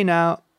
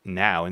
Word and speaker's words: now: Like, for example, you would say now, like now in this moment. Well, it's not pronounced as no. Yeah, it's pronounced now, even though you see an O-W in it.